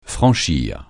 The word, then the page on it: franchir: francais